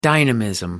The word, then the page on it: dynamism: American English